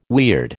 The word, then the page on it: weird: American English